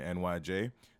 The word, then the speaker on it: Nige: People call me NYJ. To a certain degree, it's like one of the reasons why some people pronounce it NYJ is because otherwise people won't be able to find me because they'll spell it wrong, and I won't knock you on that.